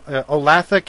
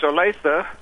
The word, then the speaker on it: Olathe: Olathe, Kansas. Am I pronouncing that right? – Actually, it's Olathe.